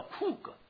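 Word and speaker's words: 库 格 (חוג): 圆圈这希伯来文叫库格，发音叫库格。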